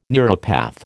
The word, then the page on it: neuropath: American English